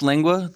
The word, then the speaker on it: lengua: But then you also have, is it called lengua or lengua? – It's pronounced lengua.